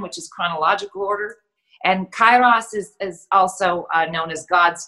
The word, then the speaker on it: Kairos: Okay, so you talk about something called Keros time. Am I saying that right? – I had to look up the pronunciation for that. Kairos time.